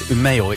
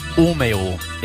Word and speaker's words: Umeå: In, I said it wrong. I said Umeå. It's Umeå in Umeå.